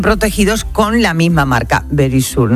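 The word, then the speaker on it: Verisure: Securitas Direct es ahora Very Sure. Eso aprendido, yo creo que ya lo sabéis, pero Verisur, ay, que me lo dice, eso es.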